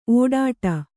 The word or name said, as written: ಒಡ್ಡು